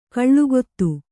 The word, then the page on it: ಕಾಲಗತಿ: kālagati